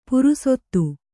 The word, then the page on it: ಪುರುಸೊತ್ತು: purusottu